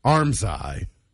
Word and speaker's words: armscye: The armhole in clothing is called armscye. It's actually spelled A-R-M-S-C-Y-E, one word, but you pronounce it like two words, armscye.